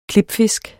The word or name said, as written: klipfisk